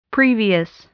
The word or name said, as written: previous